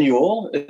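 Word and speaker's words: Juhl: No, but it was, and there's like Finn Yule. You don't pronounce the J.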